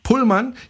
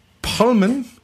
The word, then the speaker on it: Pullman: George Mortimer Pullman, ich sage jetzt immer Pullman, ah, ich hatte ja Pullman, oder wie, wie, wie muss ich das aussprechen? Pullman. Pullman. Pullman. Oder du sprichst es britisch aus, das ist ein Pullman.